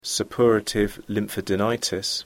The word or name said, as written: suppurative lymphadenitis